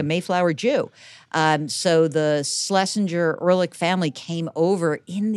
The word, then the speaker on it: Schlesinger: So the weird thing is that, so the name has really totally been anglicized and we pronounce it Schlesinger as if there were no CH.